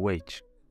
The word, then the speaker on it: witch: Cuidado com essa pronúncia, tá? É o witch.